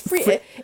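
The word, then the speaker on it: Phrygian: It's Frigian. It sounds like Frisian, but it's not spelled like Frisian cow.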